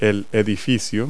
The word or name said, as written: building